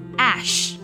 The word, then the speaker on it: Æ: 下面这个符号呢，它读作 ash，和 a s h 灰烬的这个单词呢发音是一样的。